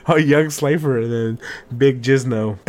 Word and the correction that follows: Jinzo: Oh, it's not Jizno. It's Jizno is what your lady says to you. – Yeah. Jinzo. That's how you say it. – Yeah. – I'm Big Jinzo. Not Jizno.